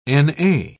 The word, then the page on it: na: British English